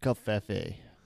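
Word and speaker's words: covfefe: Hashtag Kofif. – Hashtag Kofeve. – I think it is pronounced Kofefe.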